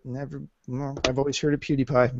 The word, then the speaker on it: PewDiePie: It's PewDiePie. That's how you say it.